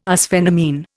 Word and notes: arsphenamine: Indian

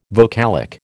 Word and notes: vocalic: American English

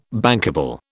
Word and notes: bankable: British English

